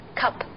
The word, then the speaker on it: cup: 对，但是这个单词 cup，它中间这个 u，它的发音呢就和字母本身音不一样，它发的比较短促 cup。